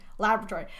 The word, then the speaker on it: laboratory: I know, I had to give it the British pronunciation, laboratory.